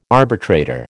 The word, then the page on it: arbitrator: American English